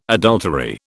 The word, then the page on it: adultery: British English